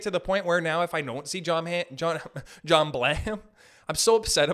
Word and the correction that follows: John Hamm: about it, I can't even say his name right. John Hamm. John Hamm.